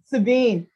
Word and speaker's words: Sabine: Is it Sabine? But you know what? It's actually French.